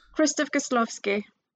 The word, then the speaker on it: Krzysztof Kieślowski: Krzysztof Kieślowski.